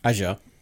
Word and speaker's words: Azure: Azure.